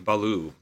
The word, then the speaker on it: Ballou: That even Kurt Ballou, when he, am I pronouncing that right? I don't think I've ever heard his name said. – I think it's pronounced like Baloo, like the bear from the Jungle.